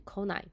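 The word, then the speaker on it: 来ない: 第三类动词需要将 kimas 改成 konai，这时候请注意发音，不来的发音是 konai。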